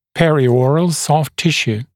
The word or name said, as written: perioral soft tissue